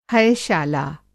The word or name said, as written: हयशाला